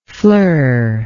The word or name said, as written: fleur